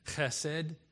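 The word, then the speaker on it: chesed (חֶסֶד): Because Hebrews have a lot of words that are they're called gutturals. So they're pronounced like from the throat. Like when you're getting ready to spit. So this word is chesed.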